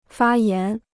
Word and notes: 发炎: fā yán

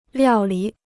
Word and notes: 料理: liào lǐ

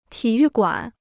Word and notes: 体育馆: tǐ yù guǎn

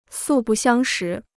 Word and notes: 素不相识: sù bù xiāng shí

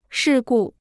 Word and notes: 事故: shì gù

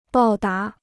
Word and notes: 报答: bào dá

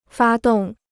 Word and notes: 发动: fā dòng

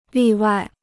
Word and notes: 例外: lì wài

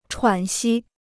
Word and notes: 喘息: chuǎn xī

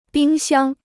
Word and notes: 冰箱: bīng xiāng

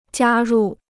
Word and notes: 加入: jiā rù

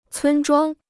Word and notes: 村庄: cūn zhuāng

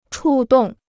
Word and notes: 触动: chù dòng

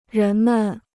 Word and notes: 人们: rén men

